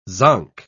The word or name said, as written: zonk